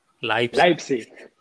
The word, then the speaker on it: Leipzig: Eh, ¿Cuál es, la pronunciación correcta? – Leipzig. – Leipzig.